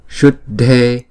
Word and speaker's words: Shuddhe: Tương tự như vậy, chữ S này ển cong lưỡi lại. Shuddhe, Shuddhe. Chữ Shud là một nhịp, Dhe là hai nhịp.